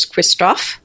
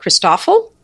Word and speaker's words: Christofle: Uh, there's Christofle, uh, Christofle, and uh, Puiforcat. The Christofle is, people in America sometimes call it Christoffel because it's because of the way it's spelled. And, and that sounds awful.